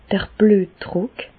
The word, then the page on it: d'r Blüetdrùck: Alsatian; Bas-Rhin; Reichshoffen